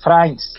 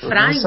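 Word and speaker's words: Frijns: é a pronúncia é Frains. – Frains? – Lá, é, Frains, é.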